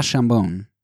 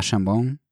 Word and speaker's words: Chambon: But, and that's particularly bad here because I heard that La Chambon is, if you say it wrong, which is easy to do for non-French speakers, that you're basically saying ham, and the town's name is not ham.